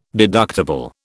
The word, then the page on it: deductible: British English